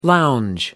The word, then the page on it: lounge: American English